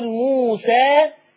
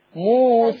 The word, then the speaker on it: موسى: احنا مش بنكتب كلمه موسى بالياء. بس بتتنطق الف. ادي اسمها ذوات ياء. يعني ده مؤقتا. فذوات الياء لورش يا اما بيفتحها زي حفصه عادي جدا يقول موسى يا اما بيقللها بيبتدي يميل بالفك بتاعه شويه موسى.